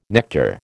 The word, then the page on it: nectar: American English